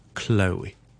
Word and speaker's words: Chloe: 咁啊，就系有啊听众咧就问 Chloe。 Chloe 究竟可以点样去读得好少少咧？咁啊，有啲人读成啊 Chloe 啦，有啲人读成啊 Chloe 啦，有啲人读成啊 Chloe 啦。咁啊，其实咧好简单嘅啫，尝试读翻个 L O E 先，我哋就咁读成 Chloe。 OK，跟住前面好轻轻地加个 k 咁嘅音就得啦，Chloe。